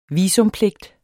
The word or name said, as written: visumpligt